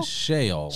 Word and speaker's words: Sheol: Sheol.